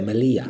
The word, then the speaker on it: Melilla: How do you say Melilla? – Melilla.